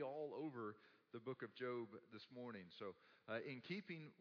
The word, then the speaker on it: Job: So open up your Bibles to the book of Job. If you're unfamiliar where Job is, it's spelled J-O-B, but it's pronounced differently, but it's before the book of Psalms.